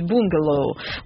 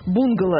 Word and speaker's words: бунгало: Причем вот это словарь Резниченко дает оба варианта, потому что слово из языка хинди пришло в русский через английский, да, поэтому получилось бунгало. В одном из вариантов вариантов нормы сохраняет ударение на первом слоге, как в языке посреднике. То есть бунгало – это скорее такой английский вариант, но у нас оно уже превратилось в бунгало.